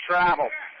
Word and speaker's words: Treve: T-R-E-V-E. That's how you spell his first name. I'm pronouncing it Trave.